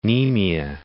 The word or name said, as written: Nemea